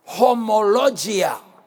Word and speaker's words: homologia: Now, the word profession in both verses, in Hebrews 4 and Hebrews 10, is the Greek word, if you're writing down, homologia. It's spelled H-O-M-O-L-O-G-I-A. Almost sounds like the word homogenous. Homologia. Homogenous.